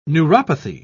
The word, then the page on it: neuropathy: British English